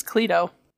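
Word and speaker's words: Cleito: I think it's Cleto. – Cleto. You're right. – Ha! – It's Cleto. That's what Wikipedia is telling me.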